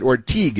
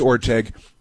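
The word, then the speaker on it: Orteig: So that's and again, they mispronounced it Orteg. It's Orteg, but that's okay.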